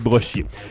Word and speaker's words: Brochier: Brochier Technologies de Villeurbanne à Rhône. Voilà, c'est Brochier. J'adore quand je prononce en anglais sans faire exprès.